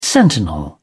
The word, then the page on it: sentinel: British English